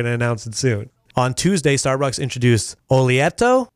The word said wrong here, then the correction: Oleato: Oleato, a line of olive oil infused drinks.